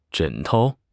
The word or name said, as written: 枕头